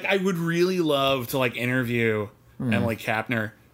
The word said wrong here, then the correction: Kapnek: Or Emily Kapnek, sorry. – Kapnek.